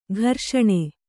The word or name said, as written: ಘರ್ಷಣೆ